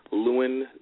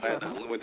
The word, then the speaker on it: Llewyn: And looking forward to the Coen's latest film, Inside Llewyn Davis. I hope that I pronounced that real name right. – You did. – Llewyn Davis.